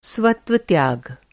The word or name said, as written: स्वत्वत्याग